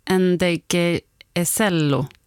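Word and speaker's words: Ndegeocello: Michelle Njegjoe. – Nej, Njegjoecello. Njegjoecello, ja. Kanske.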